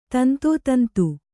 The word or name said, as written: ತಂತೋತಂತು